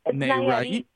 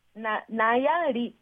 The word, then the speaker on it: Nayarit: Is that Nayarit? Nayarit.